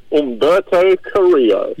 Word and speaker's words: Humberto Carrillo: I honestly, Humberto Carrillo, I can't say his name. Help me out here. – Humberto Carrillo.